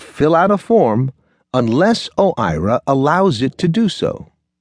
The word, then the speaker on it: OIRA: OIRA, pronounced OIRA, is Washington speak for a little office with a big impact.